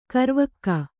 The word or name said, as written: ಕರಿವಕ್ಕಿ